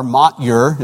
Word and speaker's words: Motyer: Alec Motyer, and that's his name is spelled M-O-T-Y-E-R, Motyer is the way I always pronounced it until I heard somebody in Northern Ireland say it, and it's actually Motyer.